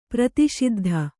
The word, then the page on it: ಪ್ರತಿಷಿದ್ಧ: kannada